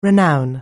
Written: renown

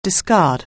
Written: discard

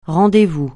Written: rendez-vous

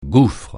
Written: gouffre

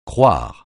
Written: croire

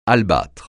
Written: albâtre

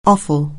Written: offal

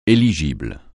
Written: éligible

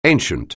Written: ancient

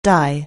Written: dye

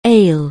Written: ail

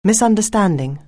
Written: misunderstanding

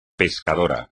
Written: pescador

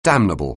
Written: damnable

